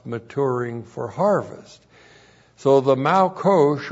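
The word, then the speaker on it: malkosh (מלקוש): M-A-L-Q long O-S-H, malkosh. So malkosh means the latter rains.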